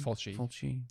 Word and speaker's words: Fulci: Am I saying that right? Lucio Fulci. – Fulci, I think. – Fulci. Yeah. Del Tenney, Gordon Douglas, John Gilling, Victor Halperin, and Steve Sakelli.